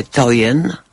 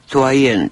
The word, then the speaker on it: Toyen: Jest dość duży wybór prac nieznanej w Polsce, a ledwie zahaczonej w Mocachu artystki, której nazwisko wymawia się na dwa różne sposoby. Mówi się Toyen, albo się mówi Toyen,